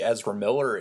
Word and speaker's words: Ezra Miller: They've got these huge plans, and they also let slip in that Ezra Miller, is that how you pronounce his name? – Yep.